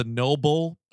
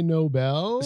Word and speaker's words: Nobel: This Martine character refers to the award that he turns down as the noble. So let's reset on that one because they couldn't say Nobel.